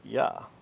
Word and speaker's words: ja: Probably the first monks to do this were around Germany or the Dutch, because the letter J in that language sounds the same as the Y in English. And as it's seen in their language today, German ja is pronounced ja, so ja is pronounced ja in German.